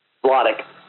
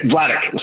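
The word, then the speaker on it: Vladeck: How do you say his name again? – Vladek. – Yeah, Vladek. Sorry.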